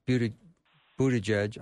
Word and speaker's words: Buttigieg: And David, no question, there are people that were listening to Pete Buttigieg. Am I saying that right? – Yes, you are.